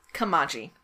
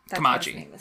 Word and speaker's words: Kamaji: Oh, no, sorry. Kamaji. – Kamaji, yeah. – K-A-M-A-J-I. I was saying it wrong.